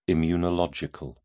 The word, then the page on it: immunological: British English